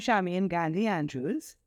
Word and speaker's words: Charmaine Gandhi-Andrews: Okay, so I'm Charmaine Gandhi-Andrews.